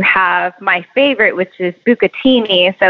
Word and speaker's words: bucatini: Bucatini.